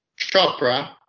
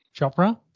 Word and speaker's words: Chopra: Chopra. – Chopra, there you go.